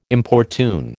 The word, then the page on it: importune: American English